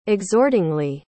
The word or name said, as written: exhortingly